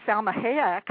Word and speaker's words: Salma Hayek: And then we have on the women, Salma Hayek.